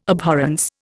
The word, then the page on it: abhorrence: Indian